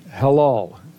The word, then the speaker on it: halal: And it's transliterated into the English word halal, halal. It's spelled H-A-L-A-L, pronounced ha-lal.